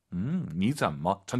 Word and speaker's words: 你怎么: 니쩌뭐 삼성삼성이니까 앞에가 이성이죠. 니 올라가야죠. 다시 한번 들어볼게요, 우리 선생님 발음. – 니쩌뭐 전체 문장.